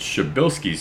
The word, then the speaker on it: Przybylski: This is a Polish name. And the way it's spelled is nowhere near how it's pronounced. It's Przybylski's star. This word starts with a P.